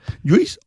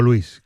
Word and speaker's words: Lluís: Lluís Pascual, ¿Lluís o Luis? ¿Cómo se dice? – Lo que tú quieras. – Me da igual. – A mí también, en Francia me llaman Lluís.